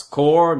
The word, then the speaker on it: Corps: euh, euh, oui, parce qu'on prononce Core, mais ça s'écrit C-O-R-P-S.